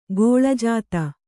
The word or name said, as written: ಗೋಳಜಾತ